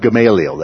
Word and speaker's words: Gamaliel: Here, Gamaliel, the pronunciation of the name is very much Gamaliel. That's pretty close, right? And so, but with some of these, we put the emphasis on the wrong syllable.